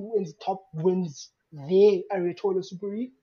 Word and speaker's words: Aotearoa: Aotearoa, but it's basically New Zealand.